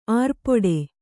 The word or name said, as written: ಆರ್ಪೊಡೆ